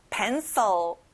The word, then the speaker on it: pencil: 연필, pencil. 친구들 앞에 펜에 강조해보세요.